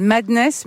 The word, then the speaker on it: MDNS: Il s'appelle Madness, mais ça s'écrit ouais, MDNS. – MDNS. – Ouais, les gens l'appellent Madness, MDNS.